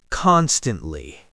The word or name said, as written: constantly